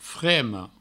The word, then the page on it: frema: fremo